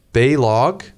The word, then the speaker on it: Balog: No, it's Balog. – Balog? – Yeah. – Okay, it's not how I would see, you know, when I see that spelled out.